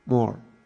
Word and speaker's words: mor: Did I say it right? Mor.